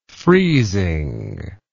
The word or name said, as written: freezingly